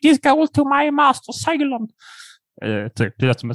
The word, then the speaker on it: Zeilon: Z-E-I-L-O-N. Hur uttalar man det? Ceylon? Ja.